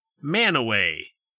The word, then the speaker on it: Mantua: Well, just take, for example, the town of Mantua, which in this state is pronounced Manaway. – Yep, that makes sense. – M-A-N-T-U-A.